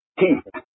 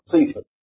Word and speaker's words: Cephas: The C here is hard. If you want to pronounce it correctly in English, it's Cephas, not Cephas.